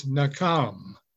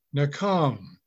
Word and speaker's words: nacham: Now, this word repent in the Hebrew is nakam. It's spelled N-A-C-H-A-M. The C has a K sound and pronounced in English. Nakam.